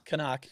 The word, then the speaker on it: CNOC: Canuck. – Yep. Canuck, yeah. – C-N-O-C.